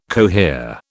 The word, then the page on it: cohere: British English